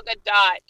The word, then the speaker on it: Gadot: It's actually Gal Gadot. Yeah. – She pronounces the T.